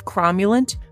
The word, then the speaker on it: cromulent: So the word is cromulent.